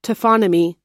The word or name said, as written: taphonomy